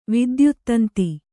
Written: ವಿದ್ಯುತ್ತಂತಿ

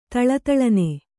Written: ಥಳಥಳನೆ

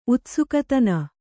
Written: ಉತ್ಸುಕತನ

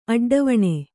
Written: ಅಡ್ಡವಣೆ

